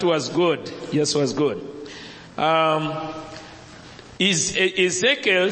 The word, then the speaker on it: Ezekiel: Ezekiel.